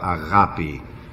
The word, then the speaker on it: agape: It's A-G-A-P-E, agape. Now, I pronounce it in the modern Greek, because I am a modern Greek, and therefore I will not go along with agape.